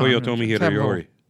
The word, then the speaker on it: Toyotomi Hideyori: Eastern forces of Tokugawa Ieyasu, which is Japanese, defeat the Western Japanese clans loyal to Toyotomi Hideyori. Leading to destruction. – You did a pretty good pronunciation there. – Yeah, I know. – Toyotomi Hideyori.